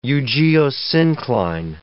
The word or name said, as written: eugeosyncline